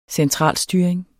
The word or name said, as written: centralstyring